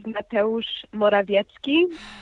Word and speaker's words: Mateusz Morawiecki: Wait, how do I say the new guy's name? – The new guy is Mateusz Morawiecki. And I was actually thinking about you, Katie, and about how you had just learned how to pronounce the earlier prime minister's name, because the new prime minister's name is also hard for non-Polish speakers.